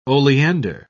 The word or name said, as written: oleander